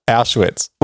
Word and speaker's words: Auschwitz: Well, again, you said it wrong immediately afterwards. Auschwitz.